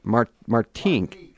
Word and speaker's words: Martinique: lasted Martinique.